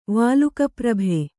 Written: ವಾಲುಕಾಪ್ರಭೆ

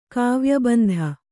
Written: ಕಾವ್ಯಬಂಧ

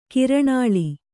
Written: ಕರ್ನಲ್ಲು

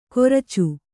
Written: ಕೊರ್ಚು

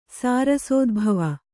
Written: ಸಾರಸೋದ್ಭವ

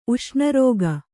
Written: ಉಷ್ಣರೋಗ

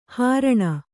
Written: ಹಾರಣಿ

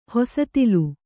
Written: ಹೊಸತಿಲ್

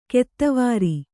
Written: ಕೆತ್ವಾರಿ

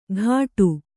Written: ಘಾಟು

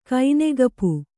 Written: ಕೈನೆಗಪು

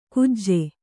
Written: ಕುಜೆ

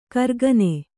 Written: ಕರ್ಗನೆ

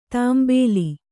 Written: ತಾಂಬೇಲಿ

